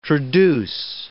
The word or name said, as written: traduce